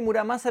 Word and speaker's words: Muramasa: Acá dije Murasama, pero fue un error mío de pronunciación, perdón, nadie me lo corrigió. Eh, Masamune y Muramasa